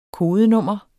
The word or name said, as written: kodenummer